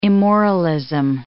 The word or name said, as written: immoralism